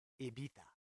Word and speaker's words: Ibiza: Ibiza, yeah, but I think it's pronounced Ibiza.